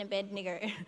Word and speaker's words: Abednego: Radshak, Meshach, and Abednego.